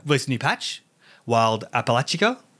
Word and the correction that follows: Appalachia: Appalachia. – Yeah, Appalachia. I keep saying it wrong.